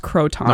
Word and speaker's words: croton: It's pronounced crouton. – I think it's pronounced croton.